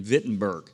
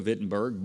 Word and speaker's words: Wittenberg: And that's the Reformation where Martin Luther nailed his 95 Theses on the church doors, the castle church doors there in Wittenberg. It's spelled Wittenberg with a W, but you pronounce it kind of in a Germanish way with a V.